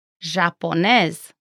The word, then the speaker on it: Japoneză: Next, the letter J sounds like the Z in azure. Here is an example. – Japoneză.